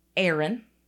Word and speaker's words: Arron: His name is Arron. It's spelled A-R-R-O-N. – Okay. – He's 23. However, although his mother apparently had meant to name him Arron, like A-A-Ron. – A-A-Ron. – She named him Arron. And that is his preferred pronunciation of the name.